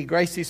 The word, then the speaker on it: Gracie: So Royce is spelled R-O-Y-C-E, Gracie spelled G-R-A-C-E, so it's Royce Grace to us, but Royce Gracie in their language.